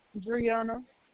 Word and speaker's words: Breonna: Brianna. – Can you spell that for me? – B-R-E-O-N-N-A.